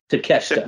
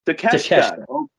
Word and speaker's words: Takeshita: Konosuke Takeshita? That close? – Takeshita. – What? Takeshita. Okay, cool.